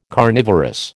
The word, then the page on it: carnivorous: American English